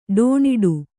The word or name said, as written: ಡೋಣಿಡು